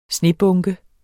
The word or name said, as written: snebunke